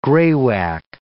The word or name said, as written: graywacke